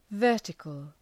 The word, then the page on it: vertical: ‘vɜ:rtıkəl